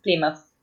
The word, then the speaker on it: Plymouth: Plymouth. – Ay, señora, señor. Plymouth. Es medio rara la pronunciación. Yo le decía Plymouth, pero no es Plymouth, es Plymouth.